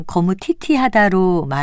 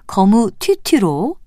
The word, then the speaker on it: 거무튀튀: 우리가 발음을 편하게 하기 위해서 그냥 거무티티하다로 말하다 보면 원래 글자도 잘못 알게 될 수도 있으니까 거무튀튀로 정확하게 발음해야겠습니다.